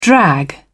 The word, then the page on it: drag: British English